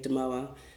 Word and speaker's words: Damoah: Yeah, so on Instagram, it's just my name, Adelaide Damoah. My last name is spelled D-A-M-O-A-H.